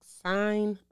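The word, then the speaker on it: Cine: I believe it's pronounced Sine.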